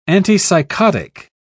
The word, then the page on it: antipsychotic: British English